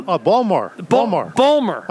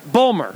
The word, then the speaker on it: Baltimore: No, No, I got a lot of family in Baltimore. Well, Ballmer. I got a lot of family there. – Ballmer.